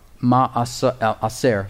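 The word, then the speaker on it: Ma'aser (מעשר): The word tithe is ma'ashar. Ma'ashar, it's spelled M-A-A-S-E-R. Ma'aser, excuse me, is how you pronounce it.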